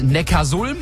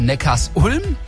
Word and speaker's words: Neckarsulm: Der Ort, der mit Ulm nichts zu tun hat, der heißt Neckarsulm und nicht Neckarsulm.